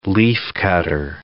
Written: leaf-cutter

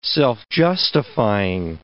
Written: self-justifying

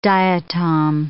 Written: diatom

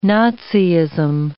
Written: Naziism

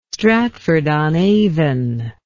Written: stratford on avon

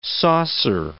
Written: saucer

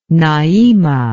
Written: naima

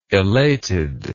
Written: elatedness

